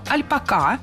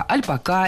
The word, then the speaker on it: альпака: Если мы заглянем в словари, например, десятилетней давности, то там мы увидим слово «альпака» с ударением на последнем слоге и с пометой «неизменяемое». Это значит, что во всех формах нужно было говорить «альпака». Подошел к «альпака», не одной «альпака», несколько «альпака» и так далее. Сейчас это слово превратилось в два слова. Он – альпак и она альпака. Ударение перебралось на второй слог.